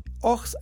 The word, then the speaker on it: Ochs: Ox and Junior, se escribe O-C-H-S and Junior, Ox and Junior, es una empresa fundada por Ludwig Oechslin Es que se escribe OE.